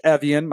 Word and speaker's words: Evjen: George Evian, my last name is spelled E-V-J-E-N.